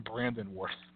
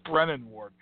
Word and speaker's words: Brennan Ward: They all called him Brandon Ward, but it's Brennan Ward, not Brandon Ward.